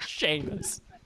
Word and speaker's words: Seamus: Fucking anyone named Seamus, you need to look at your choices of how you choose to spell your name because it's spelled Seamus. You pronounce that Seamus.